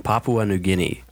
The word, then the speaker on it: Papua New Guinea: From Papua New Guinea.